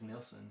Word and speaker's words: Nilsson: I believe it's Nilsson. It's N-I-L-S-S-O-N.